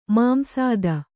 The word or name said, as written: ಮಾಂಸಾದ